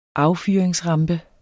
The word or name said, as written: affyringsrampe